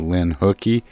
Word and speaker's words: Lynn Hukee: Yes, there's really no good way to say it in English, but Hukee, as in Hukee from school, at least people can remember that, right? – Gotcha.